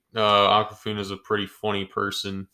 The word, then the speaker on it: Awkwafina: Shang-Chi's friend, Katie, played by Awkwafina. She was funny. Did I say her name wrong? – No, that is right, but it's such a weird name.